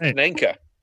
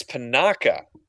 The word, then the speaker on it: Panaka: I always pronounce it Panaka. It's Panaka, I've discovered. – Okay. – I always put a second N right before the K.